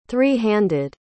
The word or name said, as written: three-handed